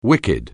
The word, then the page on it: wicked: anglais